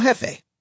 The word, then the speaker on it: Jefe: So I suggested a Mexican-sounding name, like El Jefe. I don't speak Spanish, so I didn't realize that the H sound is usually spelled with a J.